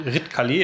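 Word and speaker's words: Ridcully: Red Kali, wird das so ausgesprochen? – Red Kali, ja.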